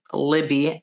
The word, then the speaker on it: Libby: If you go to the Coney Island one and you want a discount, feel free to use a code Libby, L-I-B-B-Y, my name.